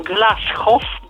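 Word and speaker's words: Glashow: I na przykład tam jest taka postać, pan profesor Sheldon Glashoff, bo tak czytano, czyta się jego nazwisko, którego tat, tatuś to był po prostu, nazywał się Lew Głuchowski.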